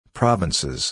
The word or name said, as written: provinces